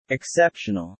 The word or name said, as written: exceptional